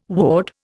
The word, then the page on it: woad: Indian